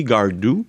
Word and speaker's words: Gardot: avait sur, sur le mur cette affiche de la chanteuse Mélodie Gardou. on dois le prononcer comme ça, bien que ça s'écrit G-A-R-D-O-T.